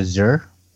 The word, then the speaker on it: Azure: I pronounce it Azure. – Azure? – Yeah. Azure. A-Z-U-R-E.